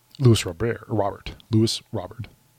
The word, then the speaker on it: Robert: On the broadcast today, Benetti and Stone were talking about Luis Robert. That's mm-hmm. how you pronounce it.